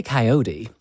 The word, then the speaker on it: coyote: Do you say coyote? – We say coyote. – Coyote? – Yeah. – Even though it's spelt with an O.